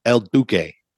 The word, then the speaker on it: El Duque: And yeah, are you sure it's not pronounced El Duque? – It is not. It is D-U-Q-U-E. El Duque. – Hmm. Never heard of him. – Sorry, D-O-Q-U-E.